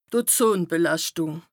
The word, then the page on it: d' Ozonbelàschtùng: Bas Rhin